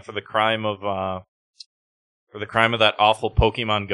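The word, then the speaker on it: Pokémon: Pokemon.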